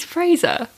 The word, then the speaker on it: Frasier: You also say his name made. Very strange. – Well, say it again. – Frasier. – It's Fraser.